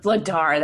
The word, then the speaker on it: Vladar: His name's Dan Vladar. – Vladar.